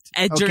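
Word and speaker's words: Egerton: All right, say it right. Edgerton.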